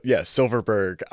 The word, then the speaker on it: Silverberg: There's something about, Silverberg.